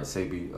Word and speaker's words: Sebi: He was gonna make the documentary about Dr. Sebi? Hope I'm saying his name right. Sebi. – Uh, you are. Okay.